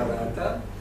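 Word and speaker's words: Ghattas: se escribe Gata, no es un peyorativo mío, se pronuncia Rata.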